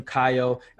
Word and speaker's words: Caio: I don't say, like, first name, Caio, because they're never going to get it.